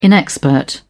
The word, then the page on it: inexpert: British English